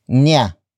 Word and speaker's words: niya: The next word is niya, which is spelled as N I Y A.